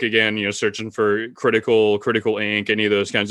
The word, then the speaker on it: KRTKL: It's spelled kind of weird, but it's pronounced critical.